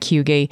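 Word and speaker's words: hygge: Actually, like there is like we're all pronouncing it wrong, but it's H-Y-G-G-E and it's something like Hughie. And it's a Danish word, and it just means being cozy.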